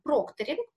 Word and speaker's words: прокторинг: Так вот, мы полагаем в школе иностранных языков, что правильно прокторинг, потому что мы используем транскрибирование, а согласно Комиссарову, это наиболее используемый в современной практике перевода способ. Поэтому вот мы рекомендуем ставить ударение на первый слог.